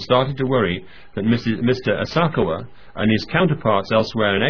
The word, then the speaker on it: Asakawa: Now, I'd never heard of this chap, this Asakawa, I think his name is, that's how it's pronounced.